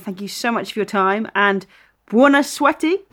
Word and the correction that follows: buena suerte: am I saying that right, to Spain tomorrow? Hasta luego? – Kind of. Yeah, kind of. – Don't be kind. How should I have said that? Come on. – Suerte. – Buena suerte.